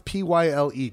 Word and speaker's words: Pyle: How would you pronounce P Y L E? Pyle? – Yeah.